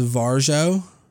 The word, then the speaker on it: Varjo: So, this company, I think it's pronounced Varjo. It's V-A-R-J-O.